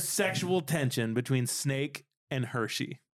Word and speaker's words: Hershe: No, because it's spelled H-E-R-S-H-E. It's Hershey.